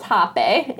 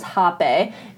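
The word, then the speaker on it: Rapé: So I know that it looks like it reads rape, but it... – Oddly enough, yes. – Oddly enough, but it is pronounced Hape, and so that you do see sometimes pronunciations or the spellings of it to be with an H, just to kind of have that distinction. – Yeah. – It does have the E with the line on the top, but for the purposes, it is pronounced Hape, and it is spelled R-A-P-E, you know? – Yeah.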